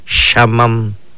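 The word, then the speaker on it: xaman: Norte, norte, lo pronunciamos de esta manera. Shamam, shamam, es norte.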